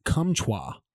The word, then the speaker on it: Comtois: You know, I might give Gibson a look at that point, but I love that you threw my boy out on here, Max Comtois. So. In case you didn't know, that's how it's pronounced. – I forgot to actually read that. Yeah. Their lack of offense, it's encouraging that Max Comtois scored 16 goals and 33 points in 55 contests, but he had the most goals in that team last season.